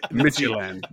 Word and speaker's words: Midtjylland: Midtjylland. Yes. – It's perfect. Yeah. I've heard friends say it's like pronouncing FC Midtjylland, but leaving out the T. Midtjylland.